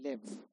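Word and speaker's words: lev (לב): Because now there's another definition of the word heart, which is L-E-B. Just trying to read it. It's actually pronounced lev, but it's written L-E-B.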